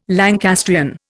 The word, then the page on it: Lancastrian: Indian